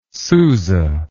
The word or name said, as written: Sousa